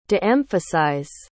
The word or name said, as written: de-emphasize